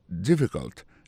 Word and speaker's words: difficult: Is it difficult? Difficult là ça veut dire difficile. Lié plus comme ça, D-I-F-F-I-C-U-L-T. Difficult. N'a pas manqué que accent tombé sur le premier syllabe là.